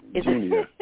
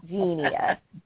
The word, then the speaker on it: Genia: Genia. – Yeah, like, I dream of Genie with an Mia with a G. Genia.